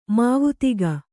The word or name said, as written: ಮಾವತಿಗ